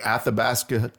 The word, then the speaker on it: Athabasca: That's from a poem he wrote called The Athabasca Trail.